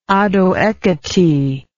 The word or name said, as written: ado ekiti